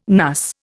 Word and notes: NUS: Indian

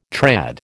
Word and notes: trad: American English

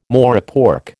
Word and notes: morepork: American English

